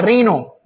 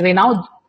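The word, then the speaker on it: Raynaud: يسموها رينو مش ريناود، رينو تنطق رينو، رينو سيندروم.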